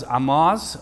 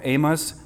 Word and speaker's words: Amoz: His father was Amoz, or Amos, as some pronounce it, and it's possible that he was born in Jerusalem.